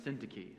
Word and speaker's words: Syntyche: It's Eodia, which looks like Yodia, Eodia, and Syntyche.